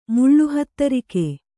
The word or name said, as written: ಮುಳ್ಳುಹತ್ತರಿಕೆ